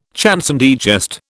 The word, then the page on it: chanson de geste: British English